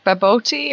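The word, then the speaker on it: bobotie: Baboti, I asked the guy, how do you say it? Baboti or Baboti? He says you can say it either way, sir, as long as you eat it.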